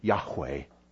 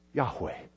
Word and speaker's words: Yahweh: How do you pronounce God's personal name? It is scholars' best guess that we pronounce the name Yahweh, Yahweh, but we really don't know.